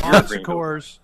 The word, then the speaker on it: Bon Secours: It's the Sam, how do you pronounce this thing? You got to help me out. – Bon Secours. Yeah, it's the Bon Secours Wellness Arena. – Bon Secours Wellness Arena.